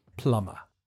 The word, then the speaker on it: plumber: The pronunciation is a little bit difficult because there's a silent B in the written form. You just say plumber.